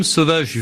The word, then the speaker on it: Sovaj: Sauvage, il faut le préciser tout de suite, ça s'écrit S-O-V-A-J.